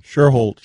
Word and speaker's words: Schuerholz: Schuerholz.